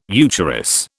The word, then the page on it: eucharis: British English